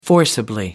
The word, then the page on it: forcibly: British English